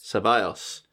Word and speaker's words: Ceballos: Ceballos.